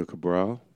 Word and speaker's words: Cabral: Daniel Cabral.